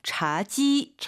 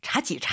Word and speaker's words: 茶几: 但是很多中国人도 자지, 자지. 因为几个几个比较容易混淆嘛. – 네, 네, 네, 네, 네. 그러니까 발음을 일반적으로 또 일부 중국인들이겠지만 자지 이렇게 삼성을 그대로 발음하는 원칙적으로 일성 지 이렇게 가야 됩니다.